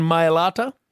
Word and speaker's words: Mailata: Jordan Mailata? – Yes.